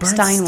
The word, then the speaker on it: Bernstein: What was the song that the Bernstein, am I saying it right? – Stein, like Bernstein. Steinway.